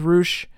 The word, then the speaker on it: Rush: I thought, for some reason, I thought it was Rush. I heard it was Roosh, but, you know, not watching ROH TV all the time, I messed that one up.